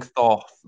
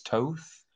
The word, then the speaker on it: Thoth: Like, I use the, I've realized that I've been pronouncing the word wrong. Thoth. It's the Egyptian god. I've been mm-hmm. saying Thoth, but I think it's Toth.